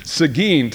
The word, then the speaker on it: Seguin: Seguin, Texas.